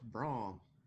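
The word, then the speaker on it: Brougham: You said Brohan. – I said Brohan. – It's pronounced Braun.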